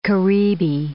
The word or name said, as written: caribe